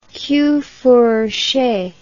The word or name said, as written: queue fourché